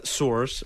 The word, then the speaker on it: sewer: Into the sewers. – Say sewer. Let's say sewer. – Sewer. – Sewer. – Sewer. – Sewer. – Sewer. – Sewer. – Two syllables.